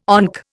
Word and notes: ONC: Indian

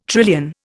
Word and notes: trillion: Indian

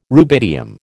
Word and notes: rubidium: American English

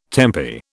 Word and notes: tempi: British English

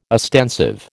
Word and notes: ostensive: American English

